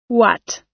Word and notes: what: American English

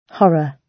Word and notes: horror: British English

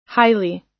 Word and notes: highly: American English

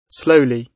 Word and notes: slowly: British English

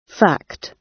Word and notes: fact: British English

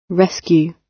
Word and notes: rescue: British English

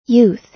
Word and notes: youth: American English

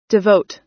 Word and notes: devote: American English

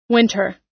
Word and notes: winter: American English

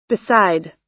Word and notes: beside: American English

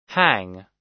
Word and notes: hang: American English

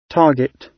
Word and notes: target: British English

